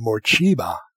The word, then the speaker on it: Morcheeba: You've just listened to Morcheeba. That's how you pronounce that word.